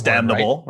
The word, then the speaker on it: damnable: Do you use the N when you're using, when you're like, because like you, the N is silent normally, but do you, when you're saying it, is that, I think that's, it's a damnable, word, right? – Yeah, okay.